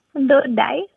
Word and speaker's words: dodai: So it's difficult because in English there is only one letter D, but in Gujarati there's four ways to pronounce the letter D. Uh-huh. And this one uses three of them. So dodai. That's three different D letters.